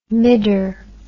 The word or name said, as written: midder